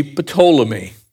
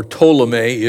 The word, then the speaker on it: Ptolemy: That would be Ptolemy, or Ptolemy if you pronounce it without the P. Some people pronounce it with, some without. And I have yet to get a straight answer even from scholars.